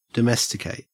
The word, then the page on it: domesticate: Australian English